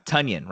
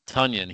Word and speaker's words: Tonyan: Tonyan rhymes with Funyan. – Tonyan.